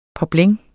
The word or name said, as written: poplin